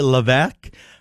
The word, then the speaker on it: Levesque: Mary Levesque, $500. And I apologize, woo-hoo, I mispronounced that last name. – No, Cynthia. That sounds right, Levesque. That's a very good French name here in Maine, my friend.